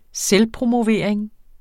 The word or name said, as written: selvpromovering